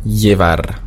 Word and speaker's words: llevar: Das Doppel-L wird ausgesprochen wie LJ, beziehungsweise nur wie J. Llevar heißt bringen oder tragen. Auf dem Festland wird es sehr oft ausgesprochen wie LJ. Allerdings hier, dadurch, dass wir viel Beziehungen zu Südamerika haben, sagt man einfach nur J. Also llevar.